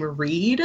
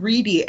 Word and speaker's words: Wrede: And I want to address right at the top that since my childhood, I have been pronouncing her last name Reed, and I listened to the audiobook of this, and they definitely said Reedy every time they said her name, and now I feel lied to by my child brain. – Yeah, it's spelled W-R-E-D-E, which definitely my brain also interprets that as a silent E. – But yeah. – It's not. It's a loud E.